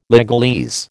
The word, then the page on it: legalese: American English